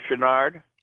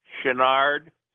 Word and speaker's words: Chouinard: Patrick Christopher Chouinard. – Spell it. – P-A-T-R-I-C-K, Christopher, C-H-R-I-S-T-O-P-H-E-R, Chouinard, C-H-O-U-I-N-A-R-D.